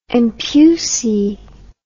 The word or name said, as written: empusae